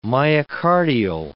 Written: myocardial

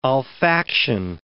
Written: olfaction

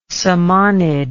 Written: samanid

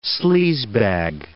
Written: sleazebag

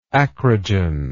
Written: acrogenously

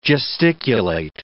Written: gesticulate

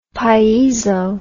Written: piezo

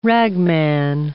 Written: ragman

